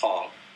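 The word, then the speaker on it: Hoang: It's still one word, but at Strider Hong. It's H-O-A-N-G. I won't get into specifics of pronouncing that in ethnic terms, but I just tell white people that it's Hong.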